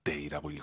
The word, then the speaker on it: 得: 동사 앞에 있으니까 데이라고 읽어야죠.